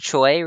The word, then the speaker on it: Choi: Choi rhymes with joy.